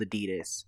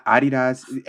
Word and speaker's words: Adidas: Yeah, they call them Adidas. Adidas. Anybody from the UK, correct me if I'm saying it wrong, but I believe they call them Adidas instead of Adidas.